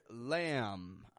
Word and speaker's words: lamb: Now, the final meat that I'd like to bring to your attention is from the animal of the lamb, L-A-M-B. Notice I'm not pronouncing the B at the end of the word lamb. I'm just making the M sound, lamb. So the meat from a lamb is called mutton. Mutton, M-U-T-T-O-N, mutton.